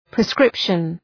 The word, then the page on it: proscription: prəʋ’skrıpʃən